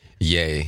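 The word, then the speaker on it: Ye: Yay? – Yay.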